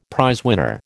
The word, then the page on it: prizewinner: American English